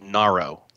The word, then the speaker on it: Narro: It's called Narro. – How do you spell that? – N-A-R-R-O. – It's almost like narrow without the W on the end of it.